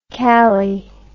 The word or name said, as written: kali